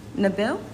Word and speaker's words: Nabil: Nabil? – Yes. – How do you spell that? – N-A-B-I-L.